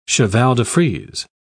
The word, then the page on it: cheval-de-frise: British English